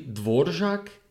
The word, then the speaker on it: Dvorak: So yeah, basically letters are all mixed up based on this Dvorak guy. Actually, a side note, isn't it actually Dvorak? I don't yes. – know. – It is. That's actually probably the correct pronunciation.